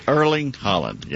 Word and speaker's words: Erling Haaland: Yeah, Erling Haaland.